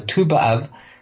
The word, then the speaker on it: Tu B'Av: We pronounce it two. So Tu B'Av, the 15th of Av is today.